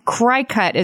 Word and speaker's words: Cricut: And I want to defend myself by saying, as a spelling bee champion who is very aware of the rules of phonics, cry cut is how you pronounce that collection of letters. – Wait, I'm going to push back on that one. It's spelled cricket and it has an antenna on top of the C. – It's spelled C-R-I-C-U-T. And the rules of phonics dictate that you would pronounce that cry cut.